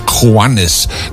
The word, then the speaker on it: Juanes: Dat was Juanes. Dat weet ik nu wel, dat een J wordt een G uitgesproken.